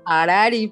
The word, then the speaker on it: Harari: Dinos cómo se pronuncia. – Harari, porque H es muda.